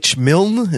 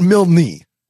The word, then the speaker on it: Milne: M-I-L-N-E. – No, I mean, it's Milne. You A.A. Milne. – No, it's definitely not Milne. It's a one-syllable last name.